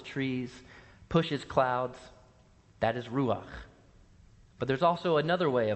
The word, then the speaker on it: ruach: And that name in Hebrew is ruach. And I invite you to say this word with me Ruach. You got to use your, use your voice for that last syllable Ruach. Yeah, there you go.